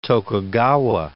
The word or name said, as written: Tokugawa